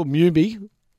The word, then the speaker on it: Mumy: What about Billy Mummy? – Bill Mumy is his correct pronunciation.